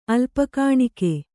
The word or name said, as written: ಅಲ್ಪಕಾಣಿಕೆ